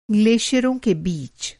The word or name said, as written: ग्लेशियर